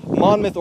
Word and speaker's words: Monmouth: I was told that I mispronounced that in my last video, so I corrected it in my last podcast. It is Monmouth, Oregon.